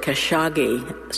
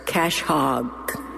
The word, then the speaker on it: Khashoggi: I didn't know they pronounced it Khashoggi, so I always pronounce it as Cash Hog.